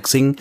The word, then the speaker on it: Xing: Hätte man nicht wetten müssen, das hörst du ja, hört ursprünglich, sollte es ja Crossing heißen. Ich sag ja auch nur Crossing, weil ich das andere nicht ausspreche. – Vielleicht sollte es auch Coca-Cola heißen, aber es sagen wohl jetzt alle Coca-Cola und es sagen auch alle Xing.